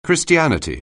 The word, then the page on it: Christianity: anglais